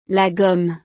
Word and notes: la gomme: French

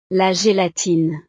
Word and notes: la gelatine: French

